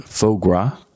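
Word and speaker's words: foie gras: Have you ever had, is it faux gras? – Faux gras. It sounds like. – Do you know what faux gras is? – Organization.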